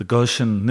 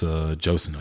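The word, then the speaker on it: Ghosn: Carlos Josen, I guess Goshen, is how you pronounce Josen, it.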